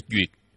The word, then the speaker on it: duyệt: Cái chữ duyệt này, trên thực tế nó là thuyết. Nó là chữ thuyết. Ở chỗ này phải đọc là duyệt. Ý nghĩa là duyệt của hỷ duyệt.